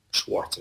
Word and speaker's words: Schwartz: Different life lessons that this individual, Mitch Albom, recorded with his old college professor, Maury Schultz. Schwartz is the correct pronunciation.